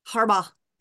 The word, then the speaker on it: Harbaugh: Harbaugh.